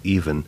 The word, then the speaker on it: Yvonne: It looks like Yvonne, but she pronounces it even.